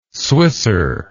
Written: swisser

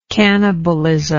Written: cannibalistically

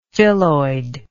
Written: phylloid

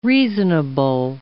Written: reasonable

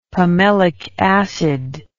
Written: pimelic acid

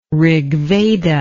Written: rigveda

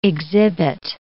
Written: exhibit

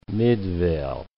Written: Midvale

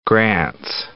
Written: Grants Pass

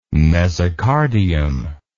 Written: mesocardium

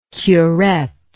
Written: curet

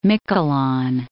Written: Miquelon

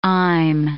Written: I'm

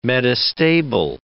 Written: metastable

